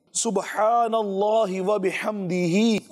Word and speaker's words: سبحان اللہ و بحمدہ: دو الفاظ ہماری اسکرین پر بھی دیکھ رہے ہوں گے, اس کا صحیح تلفظ آپ سن لیجئے, پڑھ لیجئے, سبحان اللہ و بحمدہ,